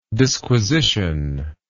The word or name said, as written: disquisitional